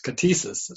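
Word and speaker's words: ktisis: I'll spell it K-T-I-S-I-S. K-T-I-S-I-S. Katesis is pronounced.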